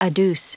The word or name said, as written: adduce